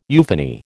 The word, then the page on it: euphony: American English